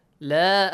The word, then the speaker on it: لا: لا has an alif مد. So, you can say لا أعبد ما, but make sure you pronounce the natural مد, okay? The آ sound.